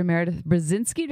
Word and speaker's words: Meredith Brezinski: And so this episode with Dr. Meredith Brezinski, did I say it right? – You did.